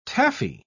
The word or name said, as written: taffy